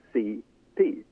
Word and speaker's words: Cipe: Yeah, one, one, one last word, uh, and that is if anybody is actually looking Cipe up, you have to know Cipe is spelled C I P E, and it's pronounced as C P, which happened to be her initials.